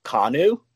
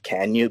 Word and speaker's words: Kanu: The funny thing is my name, I pronounce it wrong because I don't like the way that it's actually pronounced. It's actually pronounced Kanu, but that's so boring. So I'm just like, you know what? I'm just going to make it Can You because it just makes sense.